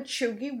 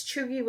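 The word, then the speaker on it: Chewgie: And since chuggy was unfamiliar to me, I wanted to know what it meant. Well, it's pronounced chew, C-H-E-W, gi, hard G, G-I-E, gi.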